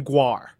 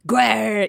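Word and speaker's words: Ghaur: It's G-H-A-U-R. And because this is a comic book, we don't know the pronunciation other than in our hearts and minds. – Can we agree yes. – to call him Gwar? – Gwar, yeah. – Like the band? – Yes.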